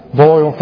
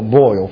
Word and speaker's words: Boyle: You remember who Susan Bowl? Did I say her name right correctly? Bowl? Boyle, thank you. I didn't think Boyle. Boyle, thank you.